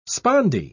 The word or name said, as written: spondee